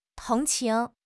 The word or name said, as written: tong2 qing2